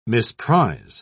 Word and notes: misprise: British English

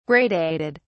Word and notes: gradated: British English

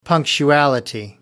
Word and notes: punctuality: American English

